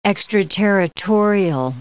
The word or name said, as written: extraterritorial